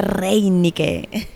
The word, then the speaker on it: Reynicke: Hola, Claudia Reinique.